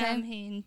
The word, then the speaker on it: Samhain: It's spelled like S-A-M-H-A-I-N. So to me, it looks like Samhain. – Okay. – Samhain, but it's pronounced Samhain because I would totally celebrate if ham was in the name back in the day.